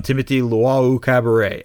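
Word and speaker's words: Luwawu-Cabarrot: And instead, he's trying to post up Dario Saric on, you know, TLC, on Timothy Luau-Cabaret. And I'm so proud of myself for saying it correctly on the first try.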